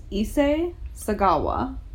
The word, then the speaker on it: Issei Sagawa: So, the cannibal that I'm going to tell you about today is Issei Sagawa. Mm. How was that pronunciation? – Yes, that was good.